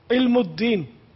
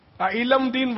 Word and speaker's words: علم الدین: علم الدین, علم دین بولتے ہیں تو تلفظ ہی غلط ہے اس کا, وہ علم الدین ہو سکتا ہے,